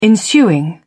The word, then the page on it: ensuing: British English